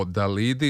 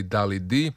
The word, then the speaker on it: Dalidi: Da, moramo nešto više o Dalidi ili Dalidi, kako bi Francuzi izgovarali njeno ime.